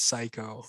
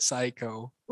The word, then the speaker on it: Saikow: So it's spelled SAIKOW. – Yeah, I had to spell that in my head. – Yeah, it's like the way you say it is the way you spell it. Psycho.